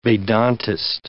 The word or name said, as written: Vedantist